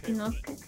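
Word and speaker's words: Shinnosuke: Chinosuke. – Ajá, sí, es que el su es, es, que la U no se pronuncia.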